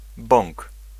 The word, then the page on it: bąk: [bɔ̃ŋk]